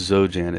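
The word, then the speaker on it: xoJane: One male vet explains that the experience on, I believe that's pronounced Zojane, it's X-O-J-A-N-E, and he says, "I'm in pain like I'm burnt.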